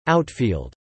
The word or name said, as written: outfield